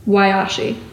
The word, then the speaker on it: Wayashe: So it's Wayashi. – Wayashi. – All right, but it's spelled W-A-Y-A-S-H-E. – Yes. – Okay, so has there ever come a time where someone has said Wayashi? – Oh, I get a lot of things. Wayashi, Washi, Weishi, Yoshi.